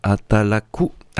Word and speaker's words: Atalaku: Voilà, on s'écoute le morceau Atalaka, ou non, comme j'ai mal prononcé, pardon, excusez-moi, Atalaku.